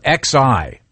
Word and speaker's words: Xi: They spell it X-I. – Yeah. – But it's pronounced Xi.